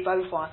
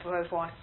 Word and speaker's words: Beauvoir: You had women like Simone Beauvoir, who wrote the Beauvoir, I think it's pronounced, who wrote The Second Sex.